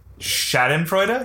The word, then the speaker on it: Schadenfreude: I'm not going to say this right, but it's that Schadenfreude? – There you go, right there.